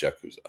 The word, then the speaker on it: Yakuza: Is it pronounced Yakuza? – It is pronounced Yakuza. That is okay.